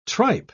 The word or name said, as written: tripe